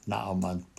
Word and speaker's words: Naaman: The second character, Naaman, or Naaman as I grew up calling him, but Naaman probably a better pronunciation.